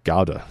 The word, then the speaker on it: Gouda: Gouda, you spell it like that, like the cheese.